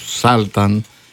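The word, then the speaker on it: Sultan: Το γράμμα γιου στην αγγλική λέξη Σάλταν βρίσκεται σε κλειστή συλλαβή και προφέρεται το σύντομο ΑΗ για την ακρίβεια όσο ήχος, όπως στις λέξεις μπας ή καπ.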